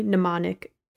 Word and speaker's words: Mnemonic: So, for example, there was one that was silent letter, 44, Across, 1995's Johnny Mnemonic EG. And it was silent M movie because the M in mnemonic is silent.